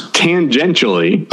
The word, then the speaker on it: tangentially: You know, this is the second time you said transgently. Are you trying to invent a new word instead of tangentially? – How do you pronounce it? – Well, there's it's tangentially.